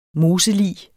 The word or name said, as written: moselig